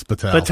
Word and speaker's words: Batal: Yeah, it's like the word battalion. It's Batal.